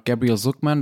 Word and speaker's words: Gabriel Zucman: Also laut Gabriel Zucman, das ist ein französischer Ökonom, den ich jetzt sehr amerikanisch ausgesprochen habe, weil er tatsächlich lange in den USA gearbeitet hat, der forscht seit Jahren zur Steuern und Ungleichheit, sieht es so aus, Deutschland verliert durch diese Gewinnverlagerung, ne?